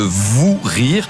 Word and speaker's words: Vous Rire: Et pour l'occasion, le Festival du Rire de Liège devient le Vous Rire. Alors ça se prononce de la même manière, mais ça s'écrit différemment. C'est V-O-U-S Rire.